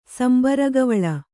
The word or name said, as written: ಸಂಬರಗವಳ